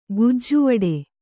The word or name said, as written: ಗುಜ್ಜುವಡೆ